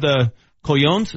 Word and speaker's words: cojones: you know the guy's skill set, and had the cojones, is that how you pronounce yes. – that? The cojones.